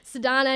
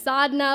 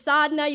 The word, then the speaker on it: Sadhana: my name's not Sadhna anymore, it's Sadhna, like,